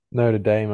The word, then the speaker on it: Notre Dame: I don't know how you say that. Notre Dame's in France. Notre Dame Notre Dame, I think is how Americans say it, yeah.